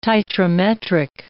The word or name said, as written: titrimetric